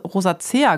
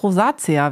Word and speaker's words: Rosacea: Ich habe immer fälschlicherweise Rosacea gesagt, aber sie heißt Rosacea, wie ich gelernt habe.